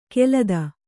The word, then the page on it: ಕೆಲದ: Kannada